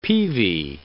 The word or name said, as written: peavey